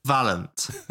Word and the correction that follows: Valorant: Tell me what it is. – Valorant. – Valorant. Okay, Valorant.